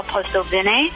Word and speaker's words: Postelvina: I'm Danielle Postelvenet. So that's spelled P-O-S-T-E-L-V-I-N-A for my last name.